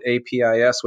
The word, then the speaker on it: Apess: Um, so uh, that that's that's I, I, I, could have gone different ways, but I, I still say apis, and, and I know there are others who who would go a different most, route with that. Yeah, most scholars that I've heard pronounce it apis.